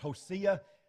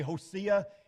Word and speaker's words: Hosea: By the way, you ever heard his name pronounced Hosea? I didn't hear that until I got to college. And apparently that is the proper way to pronounce it. So if I say Hosea instead of Hosea, you know what I'm talking about.